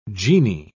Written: genie

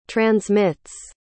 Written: transmits

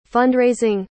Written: fund-raising

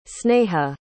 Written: sneha

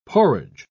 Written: porridge

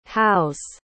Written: hause

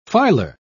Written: filer

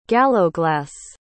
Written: gallowglass

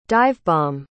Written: divebomb

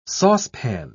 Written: saucepan

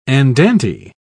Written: andante

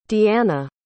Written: deana

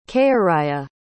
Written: khayriyya

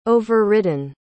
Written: overridden

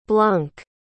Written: blunk

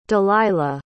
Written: delilah

